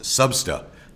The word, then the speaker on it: SBSTA: And the acronym for that, SBSTA, is pronounced SUBSTA, part of the Secretariat of the UNFCCC.